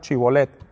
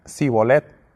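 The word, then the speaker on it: Shibolet: Entonces le decían, a ver, di pues la palabra chibolet. Pero él decía, Sibolet, porque no podía pronunciarla correctamente.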